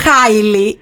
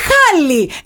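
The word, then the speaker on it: Halle: Μα δεν είναι Χάιλι, είναι Χάλι. Εγώ φταίω που είναι Χάλι. – Όχι, ρε, εσύ, ναι. Εντάξει, άμα ζούσε Ελλάδα θα είχε φάει μπούλινγκ. – Αυτό. είναι η Χάλι Μπέρι, κουκλάρα στα 62, της 62, πόσα κλείνει, 60, τέλος πάντων.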